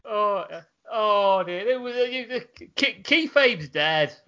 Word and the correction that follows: kayfabe: It's kayfabe. – Whatever. It's so dead I can't even say it right.